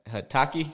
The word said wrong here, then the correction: Hibiki: Hibiki, bro. Hibiki.